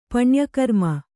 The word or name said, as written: ಪುಣ್ಯಕರ್ಮ